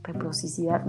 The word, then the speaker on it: reciprocidad: Reprocidad. reciprocidad nunca se Reciprocidad.